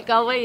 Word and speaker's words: Galway: We're going into Dublin, then we're going up to, I'm going to pronounce it wrong, Galway? – Yeah, Galway, yeah.